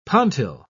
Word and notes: pontil: British English

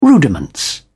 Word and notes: rudiments: British English

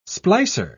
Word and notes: splicer: British English

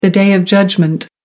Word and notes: day of judgment: American English

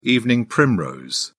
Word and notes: evening primrose: British English

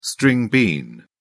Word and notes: string bean: British English

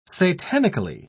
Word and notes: satanically: British English

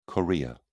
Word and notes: chorea: British English

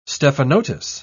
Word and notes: stephanotis: British English

